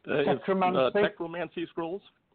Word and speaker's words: Techromancy Scrolls: Is it Techromas? You're going to have to pronounce this for me. – Techromancy Scrolls. – That's the one.